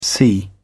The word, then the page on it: see: British English